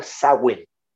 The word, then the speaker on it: Samhain: Eh, eh, existía efectivamente una celebración celta muy antigua llamada Samhain. – Samhain. Se escribe Samaín, pero en realidad se pronuncia Sawin, ¿ya? Porque es una, es una lengua, el gaélico, ¿no? La lengua de los celtas.